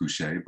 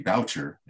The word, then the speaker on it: Boucher: My last name is pronounced Boucher, but I found out in my 20s that it's traditionally it should be Boucher, and that it was mispronounced due to some French nuns who were part of my father's kind of interesting childhood.